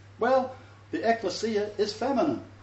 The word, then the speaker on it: ecclesia: Did I say ecclesia? Yeah, not ecclesia. Get it right. Ecclesia. Everybody say ecclesia. – Ecclesia. – Okay, do not forget it. That's how you pronounce it. Not ecclesia. Ecclesia.